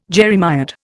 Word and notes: jeremiad: Indian

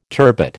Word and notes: turbot: American English

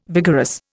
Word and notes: vigorous: Indian

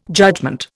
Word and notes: judgement: Indian

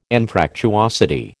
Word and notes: Anfractuosity: American English

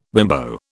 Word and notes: bimbo: British English

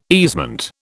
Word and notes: easement: British English

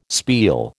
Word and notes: spiel: American English

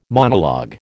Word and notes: monologue: American English

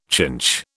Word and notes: chinch: British English

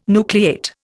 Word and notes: nucleate: Indian

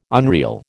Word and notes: unreal: American English

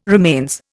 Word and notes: remains: Indian

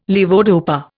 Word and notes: levodopa: Indian

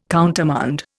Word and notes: countermand: Indian

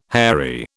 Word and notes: hairy: British English

